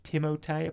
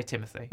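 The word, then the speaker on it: Timothée: You know it's pronounced Timotei, apparently. – Is it? – But you can also say Timothy. – I say Timothy. That's fine. – I've never heard it pronounced Timothy. – Oh. Apparently it's French.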